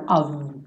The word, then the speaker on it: of: Todo ov vai ser pronunciado ov com V, né? Esse OF vai ser ov, toda vez, sem exceção, ok?